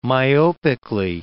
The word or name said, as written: myopically